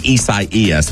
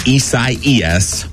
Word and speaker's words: Isaias: Okay, so she's saying Isaias. When I saw it yesterday, I was like, so its name is Isaias. But no, that is not how you say it. It is Isaias.